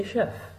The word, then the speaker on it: chef: Do you know why? You are a chef, like SH, she, not H, CH.